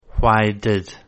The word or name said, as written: why did